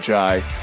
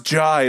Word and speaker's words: Jai: And like when a lot of people would, like the professors, the first day of class or whatever, they would pronounce it as J, and he would be like, it's pronounced Jai. It's like hi with a J.